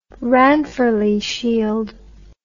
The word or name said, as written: ranfurly shield